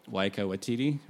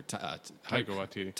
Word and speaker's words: Waititi: played by Taika Waititi, or Taika Waititi. – There you go. I always miss say his name.